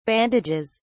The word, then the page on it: bandages: British English